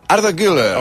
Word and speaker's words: Arda Güler: Arda Güler.